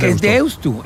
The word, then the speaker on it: Deusto: ¿Qué haces con Deusto? – Es Deusto, porque se escribe con U final.